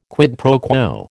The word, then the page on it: quid pro quo: American English